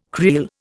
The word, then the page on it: creel: Indian